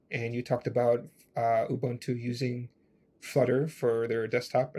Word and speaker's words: Ubuntu: And he is the engineering manager for Ubuntu Desktop.